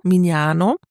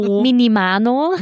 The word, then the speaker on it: Mignano: Mignano. – Genau, richtig ausgesprochen. Da gibt es nämlich viele Alternative, Minimano.